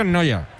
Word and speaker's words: Neuer: Yo digo, ese se pronuncia, es que es Neuer, pero se pronuncia casi igual. Sí, sí. – Sí, sí, se pronuncia Noya.